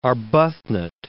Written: Arbuthnot

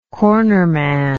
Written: cornerman

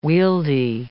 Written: wieldy

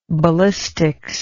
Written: ballistician

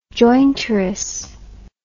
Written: jointuress

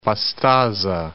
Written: Pastaza

